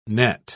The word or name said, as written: net